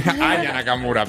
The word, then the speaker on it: Aya Nakamura: Tampoco se sabe si en la ceremonia inaugural va a cantar Ana Nakamura, que es la Naya Nakamura, ¿no? Lo he dicho bien. Aya Nakamura, perdón.